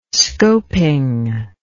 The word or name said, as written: scoping